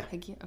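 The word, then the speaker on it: Hagiya: Is that how you pronounce it? – Yeah. – Haguia? Okay. – Yep. – In my head, that's not how I say it. – Well, that's how they say it on CrossFit Games anyway.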